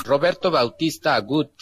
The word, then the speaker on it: Roberto Bautista Agut: Roberto Bautista Agut.